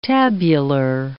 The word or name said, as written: tabular